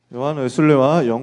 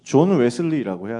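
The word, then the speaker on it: John Wesley: 요한 웨슬레와 영국의 부흥 운동인데요. 정확하게 따지면 발음을 존 웨슬리라고 해야 됩니다. 요한 웨슬레라고 하면 영국 사람들은 못 알아들어요. 존 웨슬리입니다.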